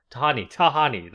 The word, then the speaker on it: Tahani: Played by Jamil named Tahiti. – Yeah. – Tahani. That's how you say her name.